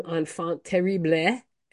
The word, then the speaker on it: enfant terrible: exactly a year ago, we reported that the one-time enfant terrible.